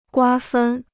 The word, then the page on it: 瓜分: guā fēn